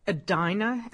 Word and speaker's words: Edina: Now, this place is in Edina. Am I pronouncing correctly? – Yeah, exactly.